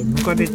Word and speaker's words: 百足: 日本だとね、百足って書いてムカデって読むんですよね。